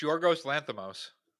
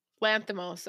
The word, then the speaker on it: Yorgos Lanthimos: It's Yorgos Lanthimos. – Lanthimos. Okay. Yep. perfectly. Nailed it.